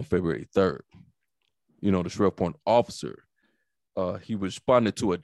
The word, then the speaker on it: Shreveport: Am I saying it right? – Shreveport.